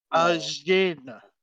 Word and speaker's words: Ajin: Ajin. – Ajin? – The word Jin. – Yes. A-J-I-N. It's spelled really, really interesting. – So Ajin, like Cajun, but without K? – Kind of?